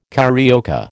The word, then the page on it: carioca: American English